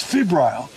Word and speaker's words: febrile: This is the technical for this is the it's febrile. Did I pronounce it right? – I nailed it.